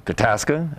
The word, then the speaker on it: Tadaska: Tadaska, as in Alaska, Nebraska.